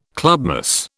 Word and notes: clubmoss: British English

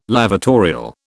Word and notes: lavatorial: British English

